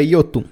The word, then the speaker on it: Jotun: É, se for nórdico, é Yotun.